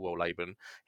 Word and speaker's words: Wohlleben: and I looked up how to say his surname, he's German, Peter Wohlleben.